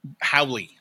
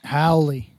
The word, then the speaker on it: Haole: So the term is Haole. – Haole.